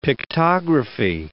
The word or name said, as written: pictography